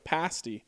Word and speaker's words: pasty: And Ryan, you were correct on the pronunciation of pasty, a long E sound at the end.